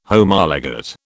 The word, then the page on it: Homologate: British English